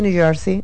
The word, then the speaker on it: New Jersey: ¿Cómo se dice New Jersey? ¿Tuvo bien pronunciado? – Sí, muy bien, gracias.